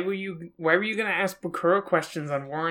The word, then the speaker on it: Bakura: Okay, so maybe just to be clear, we should be using the Japanese pronunciation and just say Bakura.